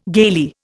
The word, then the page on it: gaily: Indian